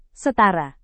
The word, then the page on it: setara: Indonesian